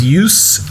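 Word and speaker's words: jus: Just, like with no T, J-U-S, apostrophe. – It's pronounced use. It's a sauce made from the drip.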